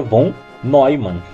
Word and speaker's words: von Neumann: Bom, pelo que eu vi na Wikipedia, o nome do John von Neumann se pronuncia von Neumann mesmo.